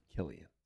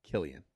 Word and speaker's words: Cillian: Cillian. – C-I-K-I, whatever. – Cillian. – Okay. – It's C-I pronounced Cillian.